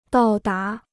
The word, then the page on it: 到达: dào dá